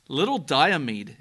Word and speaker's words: Little Diomede: Little Diomede.